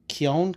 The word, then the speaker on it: Keown: David Cohen? How do you pronounce that? Dale Cohen, Dale Keown, Keown, was the artist of Hulk the End, or as it's called, The Last Titan.